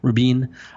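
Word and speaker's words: Rubín: we get art by Dave Rubin, or I don't know how to pronounce it. There's an accent on the I there. Rubin.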